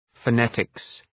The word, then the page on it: phonetics: fə’netıks